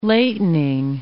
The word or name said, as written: latening